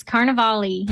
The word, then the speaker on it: Carnivale: It's Carnivale. – Perfect. – Okay, so we needed to pronounce the E.